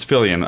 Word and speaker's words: Filion: Is it Italian? – No, it's French. – Is it? – Yes. – Filion. – So it's Filion. I pronounce it.